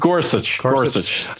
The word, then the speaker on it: Gorsuch: Gorsuch, right? That's how you say his name? Gorsuch? – Gorsuch. As I can tell, yes.